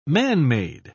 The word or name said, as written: manmade